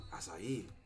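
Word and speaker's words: açaí: I believe that one's Portuguese. – Acai. – I mean, that's how you pronounce it.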